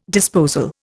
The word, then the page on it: disposal: Indian